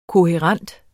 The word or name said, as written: kohærent